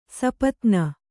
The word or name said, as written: ಸಾಪತ್ನಿ